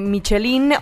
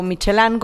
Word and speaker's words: Michelin: Michelin o Michelin, como ustedes lo quieran pronunciar en francés o en inglés, en el foro de movilidad, el foro Moving On.